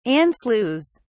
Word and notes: anschluss: British English

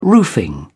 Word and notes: roofing: British English

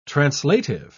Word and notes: translative: British English

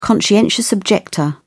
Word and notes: conscientious objector: British English

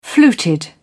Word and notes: fluted: British English

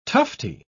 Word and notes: tufty: British English

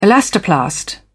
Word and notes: elastoplast: British English

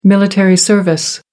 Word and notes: military service: American English

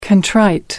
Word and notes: contrite: American English

